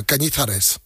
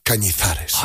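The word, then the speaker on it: Cañizares: Kilian Canizares. – C'est pas loin. Canizares. – Ah, voilà. – Ouais, pas loin. – Redis-le. – Canizares.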